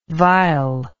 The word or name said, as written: Weyl